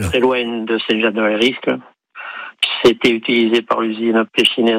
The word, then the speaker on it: Saint-Jean-de-Valériscle: Saint-Valériscle. C'est ça ? Je me trompe pas dans la prononciation, oui. – Oui, oui, c'est très bien, c'est ça.